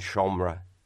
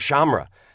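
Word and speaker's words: seomra: Slum as in L-O-M. – L-O-M. Slum and shomre. And you were helping me pronounce shomre. Just a little earlier, I was saying shamra. But as an Irish-speaking friend of mine said, you know, you can't pronounce English that well. Why would you pronounce Irish very well?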